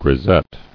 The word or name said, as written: Grisette